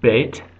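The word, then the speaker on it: Beit (בית): Beth is the second letter, commonly pronounced Beit.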